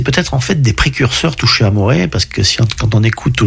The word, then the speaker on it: Touché Amoré: Alors, ça se prononce en français, il y a des accents sur les E, donc c'est Touché Amoré.